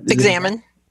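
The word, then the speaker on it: examen: And I don't know if I'm saying that word, but how do you pronounce that? – Examine.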